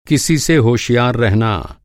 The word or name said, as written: होशियार